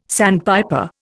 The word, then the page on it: sandpiper: Indian